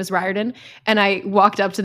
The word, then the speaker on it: Riordan: But he also said that he pronounces it Riordan.